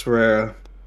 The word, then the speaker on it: Pereira: Strickland and I'm not sure how to say his name. Pereira? – Yeah, Alex Pereira. – Did I roll my R's good enough? – I think you did alright.